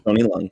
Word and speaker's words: Leung: The legendary actor, Tony Leung? Am I saying that right? – Tony Leung.